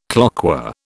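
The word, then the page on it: cloqué: British English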